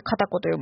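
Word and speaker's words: 賢子: 賢い子と書いて片子と読むんですけれども、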